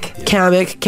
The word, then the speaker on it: Kamek: Number 8, is this Kamek? Am I saying that right? – Kamek.